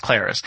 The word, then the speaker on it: Clarice: I think it was Clarice, because it rhymes with Paris, so it's Clarice.